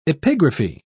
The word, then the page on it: epigraphy: British English